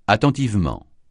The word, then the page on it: attentivement: francais